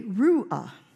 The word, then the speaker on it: ruach: And when you're talking in Hebrew, the emphasis is on the last syllable. So we would say ruach.